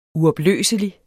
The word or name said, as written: uopløselig